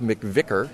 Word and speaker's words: McVicar: And the reason I'm here, and I'm at St. Jane de Chantal's Parish Hall on South McVicar. Did I come close to pronouncing it right? – That sounded absolutely perfect.